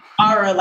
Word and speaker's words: Kiara: Kiara, like a tiara.